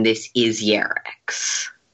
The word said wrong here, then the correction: Yarik: Did I pronounce that right? – Yeah, Yarik, but it's sorry, close enough.